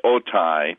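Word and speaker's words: otai (ὅτι): It's spelled O-T-I in the English, otai, and it normally means because.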